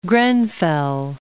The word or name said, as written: Grenfell